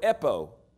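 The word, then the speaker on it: epo (ἔπω): The next one, he says, verily I say unto you in the next breath that whosoever shall say, it's the word epo, E-P-O, long O.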